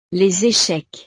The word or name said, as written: les echecs